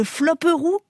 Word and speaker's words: flopperoo: Elle titre maintenant sur, je ne sais pas comment vous prononcez ça, le flopperoo ? – Oui, c'est ça.